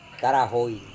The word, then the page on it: tarahoi: Serewen